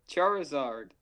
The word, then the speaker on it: Charizard: Charizard. – Charizard? – Yeah. You against the Charizard. – Because it's char, as in charcoal, as in whatever, fire, and lizard.